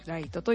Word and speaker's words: 月: ライトっていうのは月と書いてライトで、ライトと読みます。